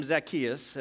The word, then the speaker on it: Zacchaeus: And there was a man named Zacchaeus, as he's properly pronounced.